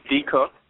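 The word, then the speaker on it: Decocq: It's James D. Cook, but it's spelled D as in dog, E-C-O-C-Q. Tough one to spell, but easy to say.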